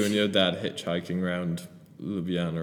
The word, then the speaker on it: Ljubljana: I was calling it the Jubla Jana the whole time. It's like Ljubljana.